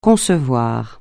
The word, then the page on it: concevable: francais